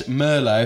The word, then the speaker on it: Merlo: Jess Merlo.